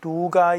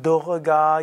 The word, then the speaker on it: Durga: Aber im Sanskrit wird das R schon wiederholt, also es ist nicht Duga Yenamaha, sondern Durga Yenamaha.